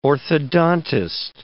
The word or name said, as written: orthodontist